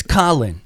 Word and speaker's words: Kahlen: K-A-H-L-E-N. Who in the hell pronounces K-A-H-K? That's Ka. Mm-hmm. It's Colin.